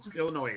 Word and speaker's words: Illinois: But if you say the noise, it's not pronounced that way. It's like I see an S. It's Illinois, and they get all mad.